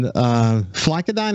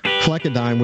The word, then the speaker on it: flecainide: In fact, I visited with Dr. Mullen recently, and after being on Flecainide, am I saying that correctly? – That's correct. – Flecainide, which is an anti-arrhythmia drug, is supposed to help your heart stay in a regular sinus rhythm.